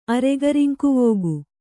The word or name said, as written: ಅರೆಗರಿಂಕುವೋಗು